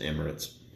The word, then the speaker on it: Emirates: That's the best way to pronounce it. Just Emirates.